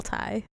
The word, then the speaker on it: Tigh: Called Tie Me Up, Tie Me Down, but it's spelled T-I-G-H. – Yes. – Because Saul Tai.